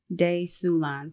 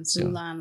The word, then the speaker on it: Sulan: Sulan. Sulan like Mulan.